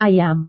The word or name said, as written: ayam